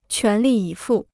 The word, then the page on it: 全力以赴: quán lì yǐ fù